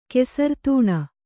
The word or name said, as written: ಕೆಸರ್ತೂಣ